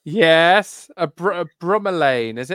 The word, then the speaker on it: bromeliad: Lewis, how do you pronounce it? – Bromeliad is the way I say it.